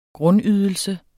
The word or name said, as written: grundydelse